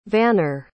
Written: vanner

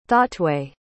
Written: thoughtway

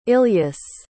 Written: ilyas

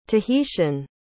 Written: tahitian

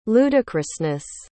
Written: ludicrousness

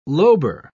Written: lobar

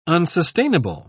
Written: unsustainable

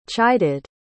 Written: chided

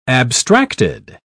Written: abstracted

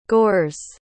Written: gores

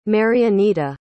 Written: marianita

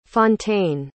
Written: fontaine

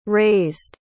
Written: razed

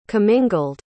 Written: commingled

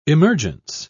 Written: emergence

